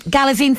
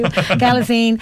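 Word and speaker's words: Galitzine: Mixing other big names into the cast, Nicholas Braun, Nicholas Galatine, I can never pronounce his surname. – Galazine, thank you. Galazine.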